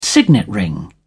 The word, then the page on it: signet ring: British English